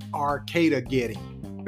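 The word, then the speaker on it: Arcadegeddon: Arcade Gedman. y'all running out of names with these games. The game looked cool, but the pronunciation is like Armageddon, but it's like Arcada Getting.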